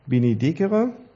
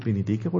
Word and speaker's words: Benedicere: Das heißt, der christliche Begriff Segen entspricht dem lateinischen Wort wiederum Benedictio, abgeleitet von Benedicere. Heißt das Benedicere oder Dezere? Ich hatte zwar mal Latein, aber das ist mit K, ne? Also wird wie K ausgesprochen, das C.